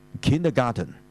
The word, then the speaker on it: kindergarten: 佢後面嗰個字咧，所以就唔係 garden，而係 garten。所以咧，你發音係跟翻個 t 音嘅，所以就係 kindergarten。